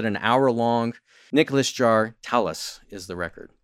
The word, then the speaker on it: Telas: Nico Jaar has a new one called Telas, and that's spelled T E L A S, Telas.